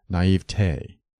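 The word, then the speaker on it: naïveté: Naivete combines naive and te, and standard word division calls for three syllables, naivete. Don't add a spurious fourth syllable. It is acceptable to stress the middle syllable, naivete, but the weight of authority favors the primary accent of the final syllable, naivete, which should rhyme with day, never with tea.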